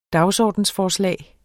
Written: dagsordensforslag

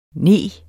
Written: neg

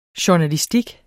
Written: journalistik

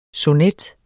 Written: sonet

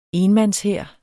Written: enmandshær

